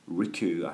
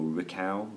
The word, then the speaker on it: Ricou: And the track is called Ricou. I hope I'm pronouncing that right. Or Ricou. Ricou. R-I-C-O-U.